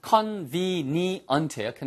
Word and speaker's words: convenient: 발음이 convenient예요. convenient. convenient. 그래서 위치아가 아랫입술의 안쪽에 가서 붙으셔야 돼요. convenient.